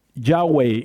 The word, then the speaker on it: Yahweh: Yahweh is the name that we think that's the way you're supposed to pronounce God's name.